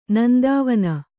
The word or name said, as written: ನಂದಾವನ